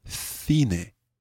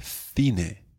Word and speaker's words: cine: The next word we're going to learn is actually pronounced a bit differently. In Spain, you would talk about el cine, el cine. – El cine. – El cine. But in many parts of Latin America, you would say el cine.